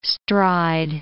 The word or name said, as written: stride